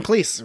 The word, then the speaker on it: Cleese: Yes, John Cleese rhymes with cheese. – John Cleese's name is... – He says it's Cleese. It rhymes with cheese.